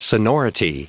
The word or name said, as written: sonority